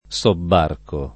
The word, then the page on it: sobbarcare: lingua italiana